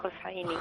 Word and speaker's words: Hosseini: No sé si lo he dicho bien o no, pero me lo va a aclarar enseguida la comisaria de la muestra. Muy buenas tardes. – Buenas tardes. Sí, bueno, sería Fátima Hosseini. – Hosseini, vale, perfecto.